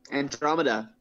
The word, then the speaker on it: Andromeda: How do you pronounce it? Andromeda. – Andromeda.